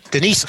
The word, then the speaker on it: Denise: Denise Foster. Und weil sie Denise heißt, mit hartem S, ne, nicht Denise, was wir sagen würden, gilt sie als Sneezy, wird die immer als Sneezy genannt. Also Denise Sneezy Foster ist die neue Trainerin.